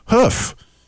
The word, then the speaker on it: kaf: And so today's show would be perfectly brought to you by the Hebrew letter hoof, which is spelled K-A-F, so don't ask me how they got the pronunciation. I don't know. But it's pronounced hoof.